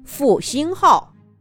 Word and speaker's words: 复兴号: 复兴号，这是最新型的复兴号动车组列车，拉上旅客可以跑到三百五十公里每小时呢。